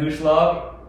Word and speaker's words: Huslage: And my socials, Evan Huslage, that's really all I use.